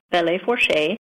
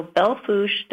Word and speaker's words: Belle Fourche: And Belle Fourche is not spelled like it sounds. It is actually a French name, Belle Fourche. It is B-E-L-L-E F as in Frank, O-U-R-C-H-E. So Belfouche Development Corporation on Facebook.